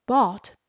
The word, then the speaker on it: bought: Bought, B-O-U-G-H-T 是 buy, B-U-Y 的过去式，购买的意思。那这边请留意 B-O-U-G-H-T 里面的 G-H 没有发音，它是念成 bought, bought。